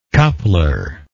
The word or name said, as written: coupler